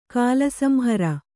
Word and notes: ಕಾಲಸಂಹರ: kāla samhara

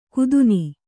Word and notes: ಕುದುನಿ: Kannada

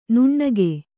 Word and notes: ನುಣ್ಣಗೆ: nuṇṇage

